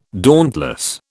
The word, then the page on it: dauntless: British English